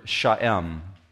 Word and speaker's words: sha'em: It's the word sha'em. It looks like shame when you look at it in English in the parentheses, but it's pronounced sha'em.